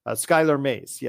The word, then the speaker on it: Skylar Mays: Skylar Mays.